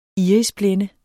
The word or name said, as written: irisblænde